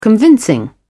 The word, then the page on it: convincing: British English